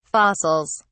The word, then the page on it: fossils: British English